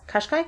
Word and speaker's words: Qashqai: Upon checking CCTV footage, the Gardaí were able to recognise the make of the Jeep, Nissan Qashqai. Is that how you say it? – Yes, Qashqai, and a further detail of the registration plate.